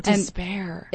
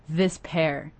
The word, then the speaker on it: Despair: Oh, Despair. – And it's people think it's Despair because it's like that's how it's spelled, but it's actually this pair. But it's like dis, like look at this.